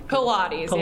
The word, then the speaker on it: Pilates: Pilates, yeah.